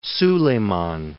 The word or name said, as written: Soliman